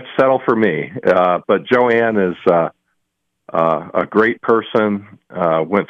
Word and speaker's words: Joan: Well, it's spelled like Joan. It's spelled J-O-A-N, but she pronounces it Joanne, her family.